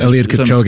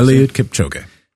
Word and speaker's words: Kipchoge: So Eliud Kipchoge is how some people say it, but yeah. – Eliud Kipchoge, now I know. – Eliud Kipchoge.